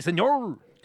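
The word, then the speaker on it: señor: When it should be like S-E-N-Y-O-R. See, senor!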